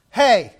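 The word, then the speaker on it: hey (ἡ): I know that doesn't look like hey, but that is the Greek letter, which is pronounced hey.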